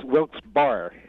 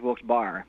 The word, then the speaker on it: Wilkes-Barre: That's the town I always pronounce Wilkes-Barre, right? – People from not around here do tend to refer to it as Wilkes-Barre. But the gentleman, that's how it's spelled, the Wilkes-Barre. – Well, the guy who is named after, his name was pronounced Barry, but who knows? – Uh-huh.